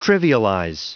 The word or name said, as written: trivialize